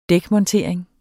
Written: dækmontering

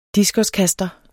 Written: diskoskaster

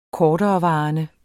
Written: korterevarende

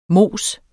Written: mos

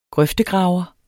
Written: grøftegraver

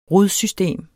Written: rodsystem

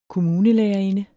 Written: kommunelærerinde

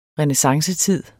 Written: renæssancetid

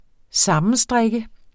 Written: sammenstrikke

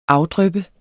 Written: afdryppe

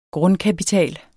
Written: Grundkapital

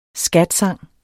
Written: scatsang

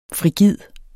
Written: frigid

